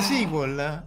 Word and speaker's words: SQL: Ah, si pronuncia SQL? Andai. Sì. Per davvero si pronuncia SQL? Che notizia, sì. Mamma. – Tendono a dire così gli americani, poi non lo so.